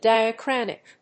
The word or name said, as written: Diachronic